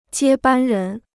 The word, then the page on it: 接班人: jiē bān rén